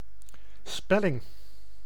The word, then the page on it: spelling: /ˈspɛlɪŋ/